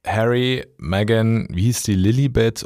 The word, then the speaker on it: Lilibet: Und die haben gemeint, man spricht es Lilibet aus. Lilibet. Okay. Also das E, es steht zwar ein E, aber es wird so ein bisschen I-mäßig ausgesprochen.